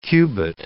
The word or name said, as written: cubit